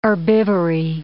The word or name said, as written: herbivory